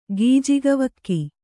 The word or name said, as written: ಗೀಜಗವಕ್ಕಿ